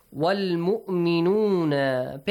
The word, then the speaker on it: وَالْمُؤْمِنُونَ: Pay attention to the hamza. Do not say... sometimes, yeah, you have to. In some kind of recitations, we do it. Wal mu'minuna. But here we should pronounce the hamza.